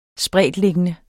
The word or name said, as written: spredtliggende